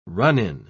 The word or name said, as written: run-in